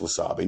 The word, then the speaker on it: wasabi: Most swabby paste isn't real swabby. I think I pronounced that wrong. – Well, you know what? Close enough. It's wasabi.